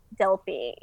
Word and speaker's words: Delpy: Delpy.